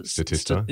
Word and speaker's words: Statista: Statista, is that how you pronounce it? – Statista.